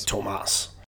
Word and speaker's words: Tomas: Thomas is spelled T-O-M-A-S. It's spelled, yes, like Tomas. – It's Tomas, yeah.